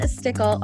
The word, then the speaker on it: Stickel: I am so excited to have my guest, Leann Stickel, on today. Did I say that right? Is it Stickel or Stickel? – No, you said it right the first time.